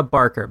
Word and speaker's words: Barker: So if you guys want to reach out, my Instagram handle is my name, Erica, E-R-I-K-A. That's spelled the German way. Erica Barker, B-A-R-K-E-R.